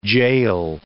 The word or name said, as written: gaol